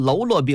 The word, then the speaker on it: 喽啰: 所以给他们起个名字叫做篓罗兵，时间一长啊，把字儿就念白了，还念成喽啰兵了。